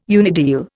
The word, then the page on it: unideal: Indian